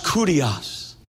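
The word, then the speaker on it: kurios (κύριος): My professor said that's pronounced kurios in the Greek.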